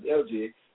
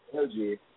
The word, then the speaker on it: ELJAI: But we locked into the pronunciation of LJ, so it was like kind of tricky. Some people say LJ, some people say LG.